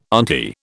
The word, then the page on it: auntie: British English